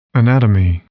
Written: anatomy

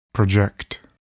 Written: project

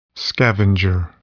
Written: scavenger

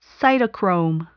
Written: cytochrome

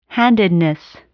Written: handedness

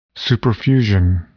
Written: superfusion

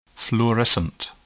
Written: fluorescent

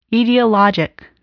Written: etiologic

